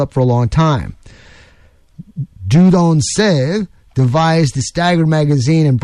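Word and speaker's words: Dieudonné Saive: It was handled by Dudon Save is how you say it in French, a Belgian firearms designer in the employ of Fabrique Nationale.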